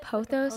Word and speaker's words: pothos: Is pythos like pothos? Is that what that is? – Pothos? – Like a pothos plant, like a golden pothos? – Oh yeah, am I saying it wrong? Pythos? I thought it was pythos. – It might be pythos, but pothos. That is my favorite pothos.